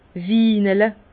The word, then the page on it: winele: Bas-Rhin; Herrlisheim